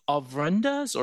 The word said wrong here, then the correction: ofrendas: or altars? – Yeah, that's right, the ofrendas.